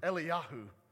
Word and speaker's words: Eliyahu: By the way that you pronounce his name is in Hebrew is Eliyahu.